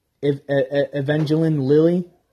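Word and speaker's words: Evangeline Lilly: what I'm trying to pronounce her name, Evangelion. Evangelion, holy shit. Eva Lily. Evangeline Lily. Jesus, I'm so bad at pronouncing names.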